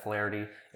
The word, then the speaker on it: Flaherty: But yeah, just reach out to me on Facebook, you know, Adam Flaherty.